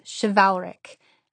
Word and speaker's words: chivalric: And so whenever I would see that word, I would think valor and be able to pronounce chivalric.